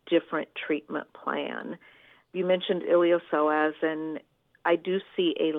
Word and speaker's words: iliopsoas: And you remember my golden retriever, Chip, who had a torn iliopsoas, and I'm really proud of myself for actually saying that correctly.